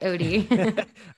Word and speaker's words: Otey: Yeah, all my social media is at Steph Otey, S-T-P-H-O-T-E-Y. You pronounced it right, Odie.